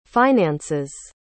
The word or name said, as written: finances